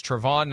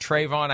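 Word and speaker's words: Travon: That's when Trayvon Walker emerged as the number one guy. I'm saying his first name wrong. As I learned last night, it's Trevon, not Trayvon.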